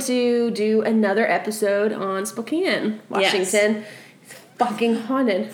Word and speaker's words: Spokane: Pronounced as Spokane. We checked. – Yes. We asked the local. We asked the pro.